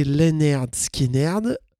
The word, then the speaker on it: Lynyrd Skynyrd: Lynyrd Skynyrd, alors Lynyrd Skynyrd, prononcé Lynyrd Skynyrd, enfin je crois,